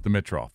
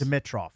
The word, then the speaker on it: Dimitroff: I've always thought his name was pronounced Dimitroff. – Okay. – And I was like throwing the I in there.